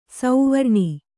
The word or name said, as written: ಸುವರ್ಣ